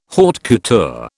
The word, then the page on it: haute couture: British English